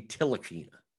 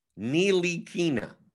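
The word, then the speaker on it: Ntilikina: Okay, so Jonathan Laureano says it's a silent T. I always thought it was a silent N. I thought it was Frankie Tilakina. So it's Frankie Nilakina. Nilikina. Nilikina. Why can't we just get rid of the T then?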